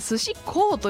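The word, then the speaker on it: 寿司幸: こちらが本当に松島寿司こうと呼ぶそうですね。幸せと書いて寿司こうこうと読むんですね。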